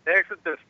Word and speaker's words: Exeter: En California, Exeter, ¿cómo se pronuncia, Exeter, amigo? – Exeter, sí.